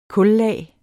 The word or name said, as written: KULLAG